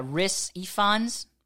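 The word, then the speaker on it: Rhys Ifans: His name is like Riz. I guess you would. Yeah, Riz Ifans.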